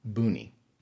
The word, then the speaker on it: Mbuni: And Boony is spelled differently than it's pronounced. So, in case you ever come across it in writing, just so you know, it is spelled M B U N I, Boony.